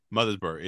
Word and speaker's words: Mothersbaugh: composer Mark Mothersbaugh. I hope that's how you pronounce it. – Mothersbaugh, it's good.